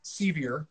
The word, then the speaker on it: Sceviour: Colton Sceviour, a guy that I think a lot of people, first of all, Sceviour, that's how you pronounce it.